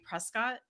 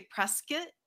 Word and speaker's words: Prescott: Another place that's really fun, if I had to pick another one, would be Prescott, but the locals here say Prescott. It's not how it's spelled. It's spelled Prescott, but a lot of people say Prescott. So it's kind of funny how I tend to do that too. My mom's like, that's not how it's spelled. And I'm like, I know, but that's just the way we say it.